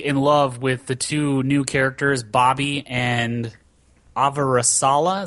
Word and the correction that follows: Avasarala: Is that how you pronounce her name? – Avasarala. – Yeah. He only knows how to say that because Avasarala.